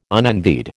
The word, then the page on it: unenvied: American English